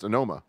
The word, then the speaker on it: Onoma: Like, we just announced Onoma, and it said exactly how it is spelled.